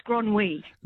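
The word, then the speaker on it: Grenouille: Yeah, and it's pronounced Gron, and his name's pronounced Gronwy.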